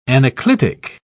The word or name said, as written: anaclitic